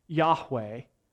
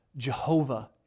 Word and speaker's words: Yahweh: In English, the letters are YHWH. Probably pronounced Yahweh. You may have heard it mispronounced as Jehovah in the past.